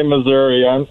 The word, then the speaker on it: Missouri: Is it Missouri or Missouri? – I still say Missouri. That's how I learned it, and so I pronounce the I, Missouri.